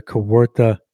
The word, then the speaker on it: Kawartha: They got the Jesse Young Award for the Kawartha Classic.